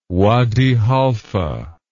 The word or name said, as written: Wadi Halfa